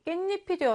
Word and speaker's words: 깻잎: 깨와 잎이 더해졌습니다. 발음을 해보니 깻잎이 되었습니다. 니은과 니은 두 개가 생겨났죠.